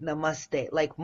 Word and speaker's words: namaste: namaste. Like must. Namaste. You must. Namaste. Because we say namaste, you know, and I'm like, why?